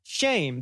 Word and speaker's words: Shem: And so these are the generations of Shem, actually pronounced shame. That's how you say his name. We say it Shem, but it's shame, which is, so you see the name Shem, as we traditionally pronounce it, means name.